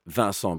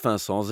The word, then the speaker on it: Vincent: Je mocht Vincent zeggen, niet Vincent. Nochtans ben ik naar een college geweest waar ze zeiden van... Uh, ja, uw naam kan perfect in het Nederlands worden uitgesproken, dus vanaf nu zal het Vincent zijn. Maar jullie mogen Vincent zeggen, alvast als je mij ook wilt noemen zoals mijn mama mij ooit genoemd heeft.